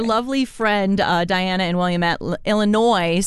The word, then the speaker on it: Illinois: Thank you to Sarah in Colorado Springs, Greg in Manitou Springs, Diana in Williamette, Illinois. I'm so sorry. – Illinois.